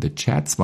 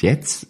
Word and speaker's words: The Chats: The Chats war nicht Jets, J-E-T-S, sondern C-H-A-T-S.